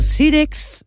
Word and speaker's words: Sírex: Uau, el sílex. El sírex, a veure si ho pronuncio bé. Sírex, que a més són catalans, són de Barcelona.